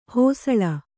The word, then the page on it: ಹೋಸಳ: hōsaḷa